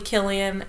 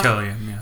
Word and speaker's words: Cillian: I know that it is probably Killian, but my brain always wants to make it a... – It's Killian, yeah. My brain always wants to make it a soft C, and I'm like, nope, that's...